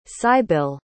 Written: cybill

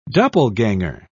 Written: doppelganger

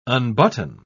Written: unbutton

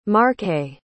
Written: marche

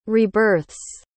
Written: rebirth's

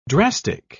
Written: drastic

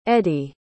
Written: eadie

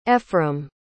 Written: efrem

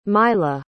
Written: myla

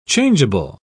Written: changeable

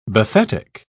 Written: bathetic

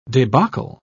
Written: debacle

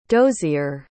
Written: dozier

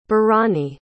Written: barany

